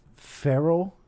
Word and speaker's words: Ferrell: It's Mary, M-A-R-Y. I think it's pronounced Ferrell, F-E-R-R-E-L-L .org. – Yeah, he's my favorite comedian. – M-A-R-Y, F-E-R-R-E-L-L .org.